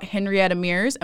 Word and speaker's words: Henrietta Mears: So today, I am going to be asking Bev some questions about Henrietta Mears. Am I saying her name right? – You are.